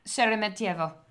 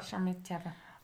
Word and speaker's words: Sheremetyevo: Sheremetyevo. Okay, there you go. In Russia. – Sheremetyevo.